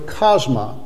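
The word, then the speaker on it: chasma (χάσμα): This is the Greek word that is used there, and you would pronounce that word chasma, from which we get the word chasm.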